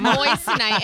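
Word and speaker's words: moissanite: No, you're not moistenite. Saying it right. It's moistenite.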